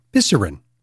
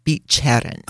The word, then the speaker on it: Bicerin: When you see the letter C followed by an E, it's pronounced Che. So Bicerin.